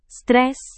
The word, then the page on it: stres: Indonesian